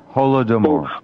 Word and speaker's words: Holodomor: The Jewish Bolsheviks also engineered the Holodomor in Ukraine between 1931 and 1934. It took 30 million Ukrainian lives. – Holodomor. – Is that how you say it? Holodomor? – Yeah, Holodomor.